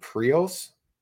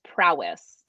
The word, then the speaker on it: Prois: But when it came to upland hunting, there was pretty much, what is that, Prios? – I believe it's pronounced Prowess. – Prowess? – You think about that sounds it's spelled P-R-O-I-S, but correct.